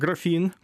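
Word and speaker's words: graphene: Тут цікаво, що англійською воно вимовляється як графін.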